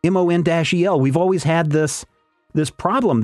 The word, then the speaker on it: Mon-El: And the editor responds, we pronounce it Mon-El.